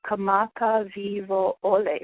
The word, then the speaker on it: Kamakawiwo'ole: So it's Kamaka Vivo Ole, or if you want to break it up into three, Kamaka Vivo Ole, and then just say it more quickly, Kamaka Vivo Ole.